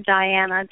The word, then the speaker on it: Diana: That's my name, Diana, D-I-A-N-A, McClintic, M-C-C-L-I-N-T-I-C.